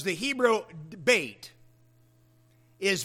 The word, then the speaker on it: Beth: B-E-T-H spells Beth in English, but it's pronounced bait, like fishing bait in Hebrew.